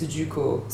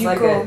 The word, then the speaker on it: Ducos: And in French, it's Ducos. So it's almost Ducos. Like a J and a D attached together, even though it's just D-U-C-O-S, and the S is silent. Juco, right? Juco.